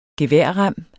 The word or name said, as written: geværrem